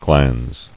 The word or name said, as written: Glans